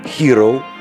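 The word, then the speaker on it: Herro: По прошлому сезону Hero получил и лучшего шестого, и лучшего дебютанта. В этом отношении он действительно герой хотя фамилия пишется несколько по-другому, чем слово «hero» в словаре.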